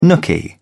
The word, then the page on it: nooky: British English